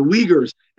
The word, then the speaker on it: Uyghur: And it's the same thing with the Uyghurs. And this is a really shitty transliteration because if you wanted to say Uyghur, I'd be like W-E-E-G-U-R.